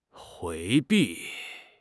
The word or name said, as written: 回避